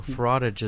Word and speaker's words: frottage: So it's frottage. – Frottage. – Like fromage, like cheese.